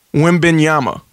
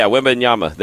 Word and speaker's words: Wembanyama: How do you say his last name again? – Wembanyama. – Yeah, Wembanyama. There you go.